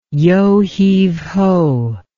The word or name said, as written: yo heave ho